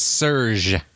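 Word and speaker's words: Serj: Serge. It's Serge. – Serge. – Oh, you spell it differently? – Yeah, it's S-E-R-J.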